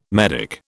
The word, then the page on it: medick: British English